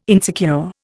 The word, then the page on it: insecure: Indian